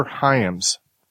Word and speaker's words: Hyams: This one is obviously the sequel, different director, Peter Hyams.